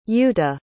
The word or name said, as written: Youta